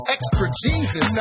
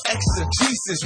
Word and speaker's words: exegesis: It's called exegesis. – Extra Jesus. – Nah, I said exegesis, man.